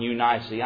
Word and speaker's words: Eunice: Now I know some of you are out there with your self-help pronunciation Bible saying, "Preacher, that's really low ice and you nicey." I know it is, but we're Southerners here, so we're going to say Lois and Eunice, all right? That's just how we pronounce it down here.